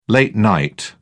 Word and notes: late night: British English

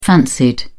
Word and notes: fancied: British English

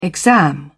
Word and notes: exam: British English

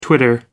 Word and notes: twitter: American English

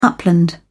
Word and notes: upland: British English

